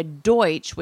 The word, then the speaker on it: Deutsch: And we found, I learned something new that I was unaware of, because I knew that the Pennsylvania Dutch, that name, was a mispronunciation of Pennsylvania Deutsch, which means German, because correct.